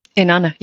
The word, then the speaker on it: Inanna: Inanna, yeah.